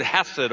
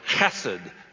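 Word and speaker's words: chesed (חסד): It's actually the Hebrew word hesed, or chesed is probably how it should be properly pronounced.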